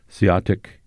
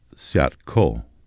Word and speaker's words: Siatic: Siatic is a Klallam pronunciation. All other tribes pronounce it Siatko.